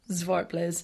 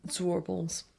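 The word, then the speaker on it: Zwartbles: It's meant to be pronounced Zwartblez, but other people, you'll hear it referred to as Zwarbles.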